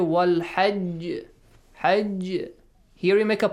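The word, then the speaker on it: Wal hajj (والحج): Wal hajj. Look, I made the qalqala sound.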